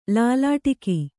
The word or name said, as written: ಲಲಾಟಕ